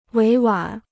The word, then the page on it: 委婉: wěi wǎn